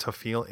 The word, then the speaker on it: Hafele: Hafele, I guess that's pronounced Hafele, H-A-F-E-L-E, Hafele, a physicist, and Richard E.